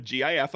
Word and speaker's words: GIF: Oh, I'm a GIF. Okay. Always have been a GIF. Always will be GIF. Because it's graphic is the first word. So GUH, it's hard G sound.